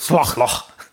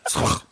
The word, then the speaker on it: Slough: We've got Slough. – That's also a real place as well. – That's the correct pronunciation for anyone who didn't know. – Slough.